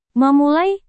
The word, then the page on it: memulai: Indonesian